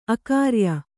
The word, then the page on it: ಅಗ್ರಿಯ: Kannada